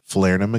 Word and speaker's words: Falernum: You've had the Falernum again. Is that how you say it? Yeah. Right.